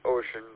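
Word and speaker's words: Wouxun: Ocean, even though it's pronounced Ocean, but it looks like it's spelt like and pronounced like Wuxon.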